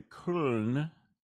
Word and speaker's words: Köln: And that's the uh, the Köln, pronounced correctly.